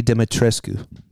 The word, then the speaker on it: Dimitrescu: That's not how you pronounce the name, dude. – Pronounce your name, dude. What is it? – Lady Dimitrescu.